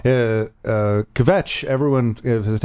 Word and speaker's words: Kvetch: So we're learning how to properly say Kvetch because the K and the V is like one thing.